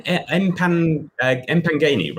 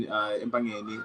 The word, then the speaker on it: Empangeni: You were in Empangeni, right? Sorry, that's bad pronounced. – Yes, no, that's exactly how you pronounce it. Yeah, so I grew up in Empangeni,